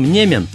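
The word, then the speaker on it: Niemen: Псевдоним Немен взял в честь реки Неман в польском произношении, на которой жил в детстве, хотя от того места, где он жил, до Немана около 40 километров.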